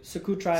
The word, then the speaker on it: Socotra: How do you say Scutara Island? Didn't you? – Socotra Island in Yemen.